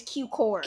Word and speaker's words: Cukor: Yeah, and so it's from 1932, directed by George Cukor. Cukor? Cukor? – Common pronunciation is Cukor.